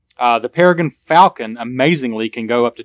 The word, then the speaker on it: Peregrine Falcon: A Peregrine Falcon. I didn't say that right. This is a bird. A Peregrine Falcon.